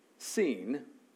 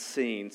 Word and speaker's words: Sin: Sin. Not sin, although it's easy. It's an easy sermon topic, but it's pronounced seen, so we won't go there.